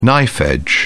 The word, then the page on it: knife-edge: British English